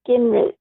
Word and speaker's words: Gingerich: G-I-N-G-E-R-I-C-H. – Like Newt Gingrich, right? – Yeah. – The E is silent, the middle E.